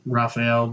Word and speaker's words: Rafael: that's Rafael Dos Anjos, or Rafael, depending on however you pronounce it. – Well, if you're from Brazil, every R is an H.